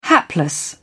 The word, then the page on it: hapless: British English